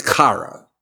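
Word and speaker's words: chara (χαρά): Now, the first one I want you to know is the Greek word chara. I'll spell it with English letters, K-A-R-A.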